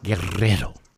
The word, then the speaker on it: Guerrero: I'm Dan Guerrero, or if you can roll your R's, Guerrero.